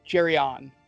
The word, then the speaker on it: Geryon: Jerian, is that his name? Uh, Jerion. – Jerion. – Yeah, it's spelled G E R Y O N.